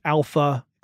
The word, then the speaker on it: ALPHV: also known as Alpha, except it's spelled A-L-P-H-V, that this group was behind the attack.